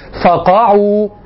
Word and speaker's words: فقعوا: وقع شيء من الاختزال في الحركات جعلك تنطق بها كلمة واحدة, والصواب فقعوا.